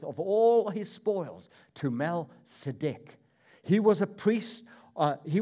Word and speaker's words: Melchizedek: Melchizedek, we pronounce him in English. But in Hebrew, Melchizedek.